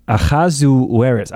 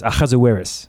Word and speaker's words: Ahasuerus: I'm reading it right from the Hebrew text. Ahasuerus. Ahasuerus. – Okay. – Ahasuerus. There's a ch in there.